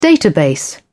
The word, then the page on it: database: British English